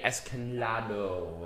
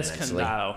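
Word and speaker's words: Escándalo: So when we come back, I'm going to be talking about the history of the event, Verano de Escandalo. I butchered that Escandalo. – Nicely. Same thing.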